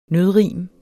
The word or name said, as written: nødrim